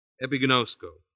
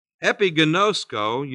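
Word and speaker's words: epigenosko (ἐπιγινώσκω): Another word here, the word new, K-N-E-W. I tell you, these verses are packed. That word new in Greek is epigenosko. E-P-I-G-N-O, long O, S-K, long O, epigenosko. Epigenosko, you know, means to know full or fully and completely.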